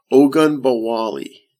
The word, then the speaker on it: Ogunbowale: Ogunbowale.